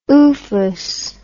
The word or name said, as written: oofus